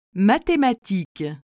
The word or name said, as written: mathematiques